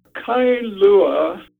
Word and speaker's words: Kailua: Kailua, two syllables, is etymologically, literally, two waters.